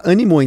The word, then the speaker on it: animaux: O nome velônimo é uma contração de duas palavras em francês, que é velo, que é bicicleta, e animaux, que se escreve animaux, mas se fala animaux, então ficou velônimo.